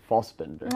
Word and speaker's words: Fassbinder: Fassbinder.